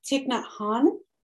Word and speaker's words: Thich Nhat Hanh: First, I need you to help me pronounce this name. – Pronounce this name. Oh, I'm going to get it wrong. Thich Nhat Hanh? – I think that sounds right.